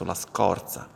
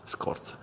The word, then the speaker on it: scorza: Nella pronuncia, mi raccomando, la O chiusa, la scorsa. Altrimenti sembrerà stiate parlando della scorza, la O è aperta, in questo caso la scorza, cioè la buccia del limone, ad esempio, o il guscio di alcuni frutti, la scorza, che viene chiamato anche così, scorza. Si scrive però con la Z e non con la S, oltre al problema della O aperta.